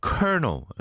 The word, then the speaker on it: Colonel: Colonel, C-O-L-O-N-E-L. 이걸 누가 Colonel이라고 생각하겠어요? Colonel이죠, Colonel. 그죠? But you pronounce it Colonel. 그 다음에 또 이제 R과 L의 조합은 참, 아, 너무 힘들죠. Rural, rural, 시골. R-U-R-A-L, rural.